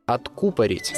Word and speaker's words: откупорить: Но если вдруг захотите блеснуть знанием слова «откупорить» – выдернуть пробку, не забывайте про ударение только на букву «у» – «откупорить».